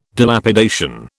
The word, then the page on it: Dilapidation: British English